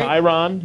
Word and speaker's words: Kyron: The Packers did not draft a quarterback, but they signed, is it Kyron? Did I say that right? – Kyron, yeah.